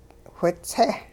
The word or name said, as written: whitse'